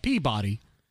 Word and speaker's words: Peabody: And there's a town in the northeast called Peabody. But apparently the pronunciation and the emphasis on the syllable is incorrect. It's actually Peabody.